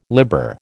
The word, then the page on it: liber: American English